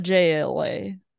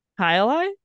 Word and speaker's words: Jai Alai: And if you have never heard of it, Hialeah is spelled J-A-I-A-L-A-I. And I went up to the bar and I said to the bartender, I would like to drink a JLA. A JLA. And he goes, Hialeah? And I go, yes, that's what I said. And he goes, that's how you say it.